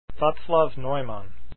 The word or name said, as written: neumann